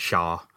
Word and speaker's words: Xia: Well, one thing's for sure, we know how to say his last name because the man himself said it, Shaw. Shaw, S-H-A-H.